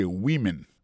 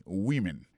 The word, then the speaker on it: women: Prononciation c'est a woman. Quand on ou, son ou. Monsieur son woman. Pays womani, c'est dans le sens où ça. A woman, ça veut dire son femme. C'est son femme au singulier. Mais tandis que c'est le mot femme au pluriel, qu'on n'a pas prononcé woman, encore c'est women, many women.